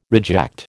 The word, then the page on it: reject: American English